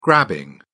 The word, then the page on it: grabbing: British English